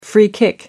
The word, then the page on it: free kick: American English